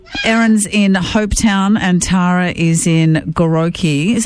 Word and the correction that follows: Goroke: Have I pronounced that correctly? – Goroke. – Thank you. As that was coming out of my mouth, I'm like, I think I'm mispronouncing that town.